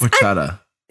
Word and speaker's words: horchata: Horchata? It's horchata. – Hey, there you go. You said it right.